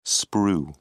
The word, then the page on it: sprue: British English